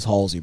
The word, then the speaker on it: Halsey: Are you saying her name wrong? – It's Halsey, bro.